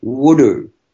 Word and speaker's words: wudu: That's called wudu, and it's pronounced W-O-O-D-O-O, wudu.